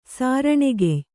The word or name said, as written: ಸಾರಣೆಗೆಯ್